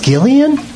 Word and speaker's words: Gillian: It's pronounced Gillian? – Yeah. – I had no idea.